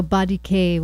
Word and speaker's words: 3DK: So Body K was the name of the, how they pronounce their band name, which is spelled 3DK, but it's Body K.